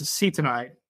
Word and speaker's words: acetonide: thank you to Let's Run podcast listener who helped correct me. It's acetonide is how you pronounce that word.